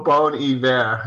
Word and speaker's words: Bon Iver: And though Bon Iver, and that Larry, you corrected me last time, I got the pronunciation right now, I believe. – Yes.